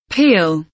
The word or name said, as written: peel